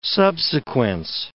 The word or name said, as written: subsequence